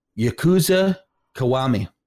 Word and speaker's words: Yakuza Kiwami: Yakuza Kiwami.